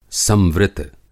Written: संवृत

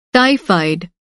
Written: टाइफ़ायड

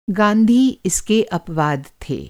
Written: अपवाद

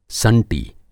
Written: संटी

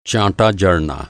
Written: चाँटा

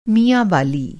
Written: मियांवाली

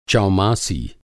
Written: चौमासी

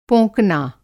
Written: पोंकना

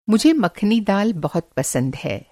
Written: दाल मखानी

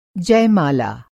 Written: जयमाला

